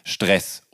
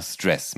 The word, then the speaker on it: Stress: Im Februar 2021, da kommt dann das Album Stress oder Stress. Man kann es halt auf beide Arten aussprechen das ist ja auch ganz bewusst so, ne? – Genau.